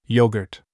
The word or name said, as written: apron